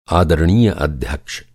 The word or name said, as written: आदरणीय